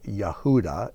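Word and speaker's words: Yehuda (יהודה): Now, the word hand is the word yud, and you can see here in the name Yehuda, which is how Judah is pronounced, you can see the first and fourth letters spell the word yud, which is hand.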